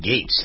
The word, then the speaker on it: Gaetz: Gaetz. That's how we pronounce his name, right? He's a Republican congressman, G-A-E-T-Z, who seems on the surface to be kind of a scumbag.